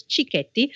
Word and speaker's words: cicchetti: So it's Cicchetti, it's not Cicchetti, okay, because CH in Italian is K, so it's Cicchetti.